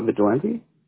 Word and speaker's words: Vigilante: All right, Joseph, I have your first name spelled J-O-S-E-P-H, correct? – Yeah. – And your last name? – Vigilante. – Spell that for me, please. – V-I-G-I-L-A-N-T-E. – Vigilante, V-I-D-I-L-A-N-T-E, correct? – G, not G.